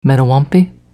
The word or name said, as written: Mettawompe